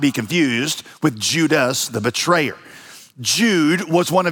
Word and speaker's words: Judas: Now, Jude's name would have been pronounced Judas in Greek.